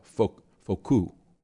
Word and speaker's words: Foucault: He's French, so I should use the French pronunciation. I called him Michel Foucault. Actually, the French is Michel Foucault, right?